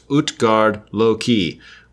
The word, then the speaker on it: Utgard-Loki: The only place where this is addressed in the lore is the tale of Utgard-Loki, pronounced Utgard-Loki, with Utgard being the word for outsider.